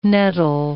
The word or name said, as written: nettle